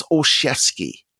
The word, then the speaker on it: Olszewski: So it's Olszewski, like Mike Krzyzewski, who is in the Final Four once again.